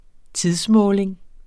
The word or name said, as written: tidsmåling